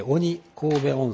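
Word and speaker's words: 鬼首: 一番上に鬼神戸、鬼の首と書いて鬼神戸温泉、鬼神戸、この首を神戸と呼んで鬼神戸温泉、その下に鳴子温泉、さらに鳴子峡で、その大崎市というのが鳴子峡の右下にありますね。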